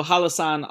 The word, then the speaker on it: Hallasan: So Hallasan on the Jeju Island, on Jeju Island, which is about 100 kilometers off the south.